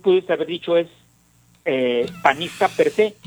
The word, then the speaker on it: per se: Fíjate, acento en la E, guinda per se.